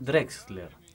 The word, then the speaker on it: Drexler: Así es, porque arranca el jueves 25, hay bandas under justamente, tocan Daniel Drexel, Barbie Martínez, para, porque acabas de pronunciar mal el nombre de Daniel Drexler.